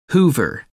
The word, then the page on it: hoover: American English